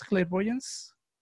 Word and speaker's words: Clairvoyance: Claire Boyance. ¿Cómo, ¿Cómo lo pronuncias? Clairvoyance.